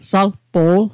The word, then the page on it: salpo'l: sal'po'l